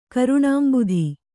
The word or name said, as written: ಕರುಣಾಂಬುಧಿ